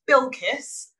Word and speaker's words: Bilkis: It's Bilkis, spelt how you say it, how you see it.